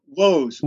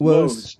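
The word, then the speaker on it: Woese: How do I pronounce it again? Carl Woest. – Woest. Like Woes is me here.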